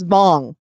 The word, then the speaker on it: Wang: His name is spelled Wang. It's not Wang. It's Vong.